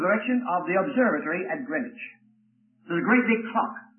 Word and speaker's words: Greenwich: Well, I was in the vicinity of a town called Greenwich. G-R-E-E-N-W-I-C-H, so W is silent. Greenwich.